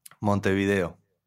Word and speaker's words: Montevideo: I just wanted to say you're in, do you pronounce it Montevideo? – Montevideo. – And for my American audience, who many Americans typically don't know where a lot of other countries are, Uruguay is in South America. Where is Montevideo located in Uruguay? – It's on the south of Uruguay.